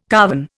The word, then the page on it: carven: Indian